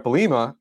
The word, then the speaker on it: Bielema: The Patriots are not the only ones who do this, but uh, you know, but this is something that happened with uh Bret Bielema, or is I'm saying his name right? Bert. Uh, yeah, you're saying it right.